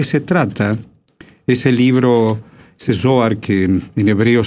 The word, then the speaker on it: Zohar: Es el Zohar. Dije la Z con Zo, pero también en hebreo también es, ¿no? – Así es.